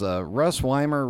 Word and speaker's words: Wymer: Klinger and birthplace of Fred Hanna, is Russ Wymer.